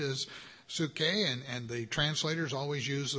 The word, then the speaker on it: psuche: and it's spelled P-S-U-C-H-E. P-S-U-C-H-E, it is psuche.